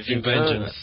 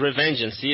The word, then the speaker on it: Revengeance: Revengeance, alguma coisa assim, qual que é a pronúncia? – Ah, acho que é Revenge, alguma coisa assim, não é? – Revengeance. – Isso, Revengeance, isso.